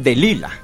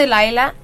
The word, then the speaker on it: Delila: Ok, oye, Delila, aquí en México tenemos la costumbre que es Dalila, y tú eres Delila. – Sí, como pronuncia mi nombre. – Ajá. – Es como de Delaila, pero okay. Delila también, sí, funciona.